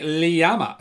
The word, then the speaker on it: llama: So there's an actual second, there's a sort of a, almost a, is it a diphthong? Am I getting that right? It's an extra syllable in between the L and the ama. Llama.